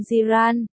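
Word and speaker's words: Ji Ran: Tên Kỷ Nhiễm trong tiếng Trung là Jiran phát âm Jiran, nên hai chữ đầu là GR.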